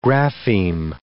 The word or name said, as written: grapheme